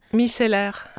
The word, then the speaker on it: micellaire: What is the correct French pronunciation? – Micellaire.